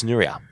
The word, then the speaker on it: Nuria: See? Nuria, not Nuria. – Yeah, Okay, I get it. – It's Nuria.